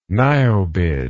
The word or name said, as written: niobid